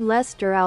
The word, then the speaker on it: Leicester: Lord Leicester aus Manchester. Lord Leicester aus Manchester. – Ja, geht doch. Und in Deutsch? – Lord Leicester aus Manchester.